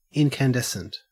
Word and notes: incandescent: Australian English

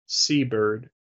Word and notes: seabird: American English

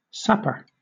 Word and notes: sapper: British English

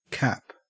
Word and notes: cap: Australian English

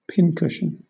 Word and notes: pincushion: British English

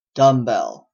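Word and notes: dumbbell: Canadian

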